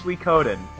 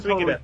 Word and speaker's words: Suikoden: So then you got Metal Gear Solid, number one seed, going up against Sudoken, or however you pronounce it. – I think it's Suikoden, and I would Suikoden.